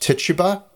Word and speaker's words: Tituba: And he brings with him an enslaved person, and her name is Tituba. But it's commonly pronounced Tituba, and that's the pronunciation I'm going to use.